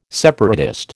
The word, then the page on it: separatist: American English